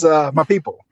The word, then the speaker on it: Mapipo: Yeah, I'm sure you spelled it correctly, but pronunciation is Mapipo. – My people. – Yeah. – It's M-A... Yeah, like people. But yeah. – Okay. – M-A-P-I-P-O.